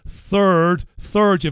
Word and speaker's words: Third: But I've got to ask you about your old friend, King Charles the Third. You've got to pronounce the TH there. Don't call the monarch a turd, whatever you do.